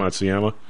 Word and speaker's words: Matsuyama: How do you pronounce his last Matsuyama. – Name? Matsuyama.